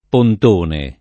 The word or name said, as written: pontone